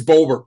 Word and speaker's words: Bober: But the alias that he used, his real name was Raymond, I'm going to say it wrong probably, but Bober or Bobber? – Bobber. I think it's Bober, B-O-B-E-R.